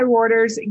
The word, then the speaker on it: Reuters: An internal WHO document seen by, you're going to have to tell me how to say this again, Reuters? – Yep. – Perfect. Okay. Seen by Reuters